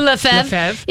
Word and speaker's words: Lefebvre: Ludo Lefebvre is in town.